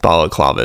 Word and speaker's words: balaclava: So me and Ash, forgive us, we totally butchered the word balaclava. Balaclava is the thing that actually goes around your head and goes over your head, protects you and keeps you nice and warm in the winter. So balaclava, forgive us for butchering it.